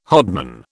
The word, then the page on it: hodman: British English